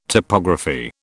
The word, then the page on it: topography: British English